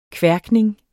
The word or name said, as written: kværkning